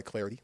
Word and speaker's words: Clardy: You can follow me on Twitter, as mentioned, at Troy Clarity. Last name is spelled C-L-A-R-D-Y.